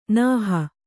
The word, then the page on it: ನೇಹಿ: Kannada